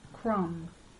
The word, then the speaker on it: crumb: B is silent after M at the end of some words. It stays silent even if you add a suffix. Numb. He was numb with cold. Crumb.